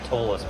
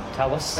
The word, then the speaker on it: Talus: I was calling it Tallus, but apparently that's wrong. – No way. – Talus.